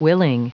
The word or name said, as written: willing